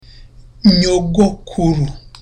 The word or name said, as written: nyogokuru